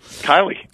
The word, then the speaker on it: Kiley: Is it Kevin Kiley or Killey? Killey? – Yeah, Kiley.